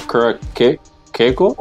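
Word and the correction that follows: Curaçao: Curacao? – Yeah.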